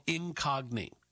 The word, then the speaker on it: Incogni: I use a company called Incogni. Incogni. I'm overpronouncing it because I want to make sure you can spell it correctly.